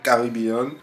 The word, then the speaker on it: Kribean: Alors, pour nos auditeurs, on précise que l'application qu'ils vont se précipiter d'aller chercher dans leur application store, ça s'écrit K-R-I-B-E-A-N, Caribbean.